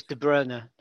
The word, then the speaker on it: De Bruyne: We're not pronouncing De Bruyne properly, are we? – Oh, go on. – I've noticed that. It's De Bruyne now.